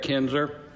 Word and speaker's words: Kendzior: We're joined today by Eric Kendzior.